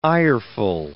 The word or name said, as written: ireful